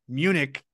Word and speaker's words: Munich: And then finally, our friend Robert Munich, I asked him how to pronounce his last name. He's like, it's like the city in Germany.